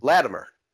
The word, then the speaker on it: Latimer: Latimer.